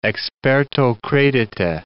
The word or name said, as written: experto credite